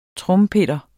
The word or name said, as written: trompeter